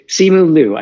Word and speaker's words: Simu Liu: But one of the prominent stars, Simu, Lu, I can't even say his name, Simu Liu, I believe.